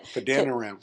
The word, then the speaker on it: Padan Aram: Arise, go to Padan Aram. Did I say it right? – Pretty darn close. Padan Aram.